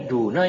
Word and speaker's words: duna: Daunaka, kok daunaka sih? Hah? Niko, antum bacanya apa? Kok daunaka? Ini bukannya duna ya? Kan duna ya? Ini kan duna, terus diidofahkan kepada domir, jadi duna.